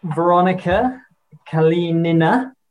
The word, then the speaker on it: Veronika Kalinina: So here I am with Veronika Kalinina.